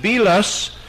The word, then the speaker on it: Belus: whose name was Bel or Belus. Some of them pronounce it Belus, and therefore it was their chief god in reference to that chief Babylonian god.